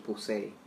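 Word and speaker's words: Poussey: Si no la conocen, ella actuó en Orange is the New Black como Pusey. Pusey. Exacto. – No como la pronunciaban varias personas.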